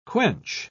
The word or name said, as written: quench